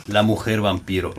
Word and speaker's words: La Mujer Vampiro: La Mujer Vampiro, l'ho detto giusto. – L'hai detto giusto, però non è completo.